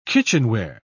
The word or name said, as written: kitchenware